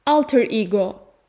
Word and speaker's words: Alter ego: Öncelikle şöyle bir şey doğru okunuşu zaten yani İngilizce okunuşu aslında Latince bir kelime. Alter ego.